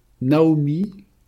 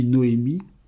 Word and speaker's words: Naomi: Naomi, que l'on prononce aussi Noémie, c'est un « Nun, Aïn, Mem, Yod ».